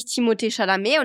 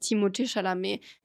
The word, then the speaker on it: Timothée Chalamet: Aber in Frankreich sagen die alle Timothée Chalamet, weil das ist ja ein französischer Name und das ist ja nicht TH, sondern es ist Timothée und dann Timothée, da ist dann danach ein H. Aber in Frankreich tut man ja diese H ist ja nicht aussprechen. – Ah ja. – Und für mich heißt er wirklich Timothée Chalamet und ich bin mir ziemlich sicher, dass es auch so eigentlich ausgesprochen wird. Nur Amerikaner sprechen es halt nicht so aus, ah, ja logisch.